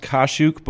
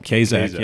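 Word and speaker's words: Kaszuk: No, we actually it's actually pronounced Kashuk, but my family Kashuk. – But my family though, when they got off the boat from Poland, somebody mispronounced it and said Kazak. So that's the way they say it. They actually say Kazak because they thought that sounded American.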